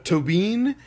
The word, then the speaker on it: Tóibín: I've been saying all the Irish names wrong, apparently. Tóibín. Who is great.